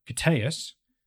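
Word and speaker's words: Koteas: On the flip side, he's got the very human Elias Kateas, and that's how you pronounce his name.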